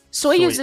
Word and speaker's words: Soyuz: Soyuz. Like, we. – Soyuz is what it is. – How did we do it? Because we I don't know. Whatever it was, we had the actual pronunciation wrong.